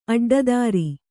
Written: ಅಡ್ಡಿತರು

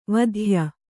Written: ವಾತ್ಯೆ

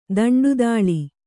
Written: ದಂಡುದಾಳಿ

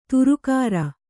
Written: ಧಾರಾಗ್ರ

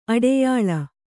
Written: ಅಡಯಾಲ

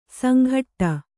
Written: ಸಂಘಟ್ಟ

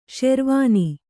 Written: ಷೆರ್ವಾನಿ

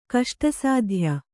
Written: ಕಷ್ಟಸಾಧ್ಯ

